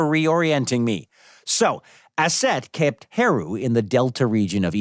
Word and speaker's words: Heru: Heru. – Heru to you too, Oracle? – No. Horus's name is Heru in the Egyptian pronunciation.